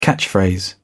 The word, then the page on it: catchphrase: British English